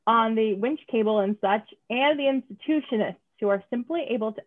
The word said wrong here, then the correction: intuitionist: Oh, wait, am I pronouncing that wrong? Intuitionist. – Oh my God, you're right. I thought it was institutionist as well. Wow. – As soon as I read that line, I'm like, we're going to have people yelling at us when we start talking about this. – But then they'll hear me. – And I have to be like, can I pronounce it correctly? Yeah. So it's intuitionist.